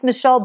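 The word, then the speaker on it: Michele: Well, my website's Michelle Borba.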